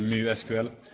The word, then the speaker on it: MySQL: So that's actually why MySQL is called MySQL, because his first daughter is called Mu. And no one ever says MuSQL, but the Swedish correct pronunciation would be MuSQL.